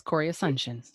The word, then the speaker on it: Cori Asuncion: Yes, Corey Assumption.